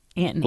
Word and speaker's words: Antony: Antony Newton Brock. – Antony? – Antony. – Like Antony Blinken? – Yeah, that's how it's spelled.